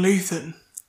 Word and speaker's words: Luton: Everton, Sheffield and Luton. Don't know why I pronounced it with a TH.